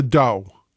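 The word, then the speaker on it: dhow: Now, if you've never heard of a DOU, D-O-U is not how it's spelled. It's actually spelled D-H-O-W, D-H-O-W. O-W, but it's pronounced do like D-O-U.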